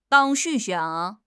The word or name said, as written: 忧郁